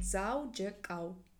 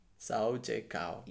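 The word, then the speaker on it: Dzaudzhikau: How do you say it? – Zaujekau. – Zaujekau. – Yeah. – Wow. Like, it ends with cow.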